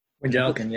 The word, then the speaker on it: Wendelken: Have I got that right? – Vindelken, yeah. – Yeah, did I butcher your name? – Nailed it, first try.